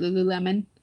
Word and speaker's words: Lululemon: It's Lululemon. That's how you say it, right? Lululemon.